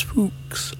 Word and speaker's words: Fuchs: Her last name is Fuchs, from the German, and it is pronounced as Fuchs. So I do apologise if you think it's a rude word. It isn't.